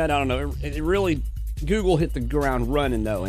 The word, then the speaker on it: googol: The name Google originated from the misspelling of Google, which G-O-O-G-O-L, which refers to a super long number. Well, one followed by 100 zeros is called Google, G-O-O-G-O-L, and it's pronounced the same way.